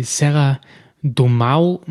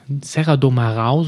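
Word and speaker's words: Serra do Marão: Und in der Region hinter Porto, flussaufwärts, ja, gibt es die Serra do Mau, Marau, Serra do Marau, so spricht man es, glaube ich, aus.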